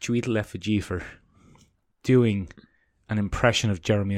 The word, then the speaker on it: Chiwetel Ejiofor: Chiwetel Ejiofor plays... – Tichuelefibor. – That's definitely not how you say it.